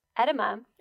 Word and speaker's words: edema: Edema. – Edema? – Not enema. – What's an edema? – Edema or edema. I'm probably saying it wrong. – Edema? – Edema. Yes.